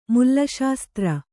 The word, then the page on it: ಮುಲ್ಲಾಶಾಸ್ತ್ರ: mullāṣāstra